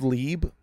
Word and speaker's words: Lieb: They look at it and they go, Lieb. I like it because it makes me feel ethnic, where I'm just like, no, sorry, it's really hard to pronounce. It's actually, it's German and Yiddish, so you probably don't understand it, but it's Lieb.